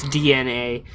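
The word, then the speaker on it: DeNA: But somebody pointed out that I pronounced it wrong. They style it like capital D, lowercase e, capital N, capital A. I said Denna. It's DNA. Whatever.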